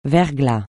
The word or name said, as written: verglas